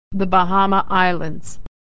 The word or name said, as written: the Bahamas